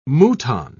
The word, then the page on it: mouton: British English